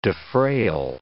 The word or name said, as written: defrayal